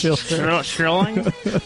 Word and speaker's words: Schilling: Dave Schilling, I just said your name wrong. – Thanks. Thank you. It's pronounced Schilling. Schilling.